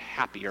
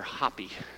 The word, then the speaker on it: Hapi: This god named Happy or Hapi. depending on how you want to pronounce it.